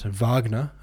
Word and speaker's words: Wagner: Wagner, pronounced Wagner.